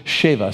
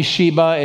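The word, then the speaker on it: sheva (שבע): It's the Hebrew word sheva. Say sheva. Maybe sheba, it's the same. The Hebrew letter can be pronounced with a B or a V, B or V. So it's sheva, and it just means 7.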